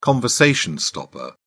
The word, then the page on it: conversation stopper: British English